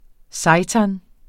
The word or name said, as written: seitan